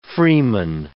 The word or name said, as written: freeman